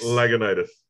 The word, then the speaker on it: Lagunitas: That's how it's pronounced? Lagunitas? – Lagunitas. – Are you serious? I've been saying it wrong this whole time.